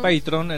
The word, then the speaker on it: Patreon: las está subiendo a una plataforma llamada Patreon, Patreon, en realidad se pronuncia.